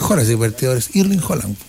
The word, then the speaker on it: Haaland: Ayer aprendí cómo se pronuncia. – Se pronuncia Haaland.